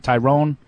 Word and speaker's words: Tyron: Is it Tyron or Tyron? Tyrone? – I love him. How do you pronounce his name? – I believe it's Tyron. – Tyron.